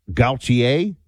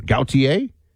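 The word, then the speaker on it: Gautier: National Hurricane Center expects it to strengthen toward 100 plus miles per hour, then shear before landfall Friday evening, sometime somewhere between Gaultier, Mississippi. Did I say that right? It's definitely French. Is that, you know, an origin? Gaultier? Like almost like Jean-Paul Gaultier, although people are going to be like, how dare you?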